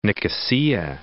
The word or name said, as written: Nicosia